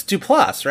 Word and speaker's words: Duplass: We will get into their partnership, starring Salma Hayek, John Lithgow, Connie Britton, Chloe Sevigny, Amy Landecker, Jay Duplass, Transparent Reunion. – Did you call him Jay Duplass? That's very funny. – What? Jay Duplass, that's his name. – It's Duplass, right? – It's Duplass.